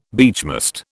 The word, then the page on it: beechmast: British English